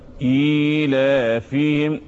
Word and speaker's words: إيلافهم: يعني بعد الهمزة في إيه؟ بص كده، بعد الهمزة في إيه؟ طب تتنطق إزاي؟ – إيلافهم. – أيوه. إيلافهم.